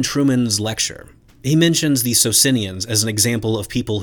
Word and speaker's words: Socinians: Now, of course, according to my Italian wife and all the Italian medievalists that I work with at Lucerne, The correct pronunciation of this Italian group is Socinians. Socinians.